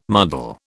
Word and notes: muddle: British English

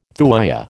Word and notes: thuya: American English

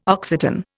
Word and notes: Occitan: Indian

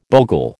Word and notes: bogle: American English

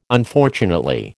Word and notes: Unfortunately: American English

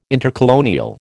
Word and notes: intercolonial: American English

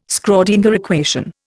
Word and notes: Schrödinger equation: Indian

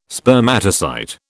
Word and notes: Spermatocyte: British English

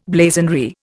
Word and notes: blazonry: Indian

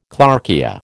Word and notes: clarkia: American English

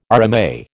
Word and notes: RMA: American English

